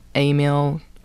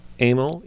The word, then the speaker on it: Emil: Well, I don't know the exact pronunciation, but when Berliner came to America, his name was Emil, E-M-I-L, and he added the E, perhaps to assimilate, to become more American, and which I call Emil.